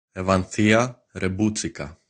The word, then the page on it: Evanthia Reboutsika: griechischen; Muttersprachler aus Athen